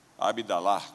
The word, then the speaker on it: Abdallah: Abdallah, como é a pronúncia? Abdallah.